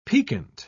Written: piquant

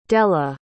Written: delle

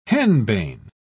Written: henbane

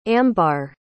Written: ambar

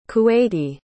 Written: kuwaiti

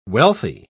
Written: wealthy

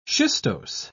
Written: schistose

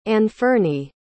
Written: anfernee

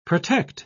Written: protect

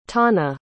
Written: thana

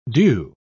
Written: due

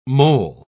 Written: mole

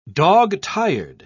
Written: dog-tired

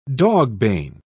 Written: dogbane

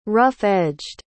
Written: rough-edged

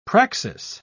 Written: praxis

